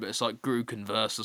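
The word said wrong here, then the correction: Gutekunst: or something like that. – Gudekunst. – Yeah, well, Gudekunst. – Yeah, that makes more sense.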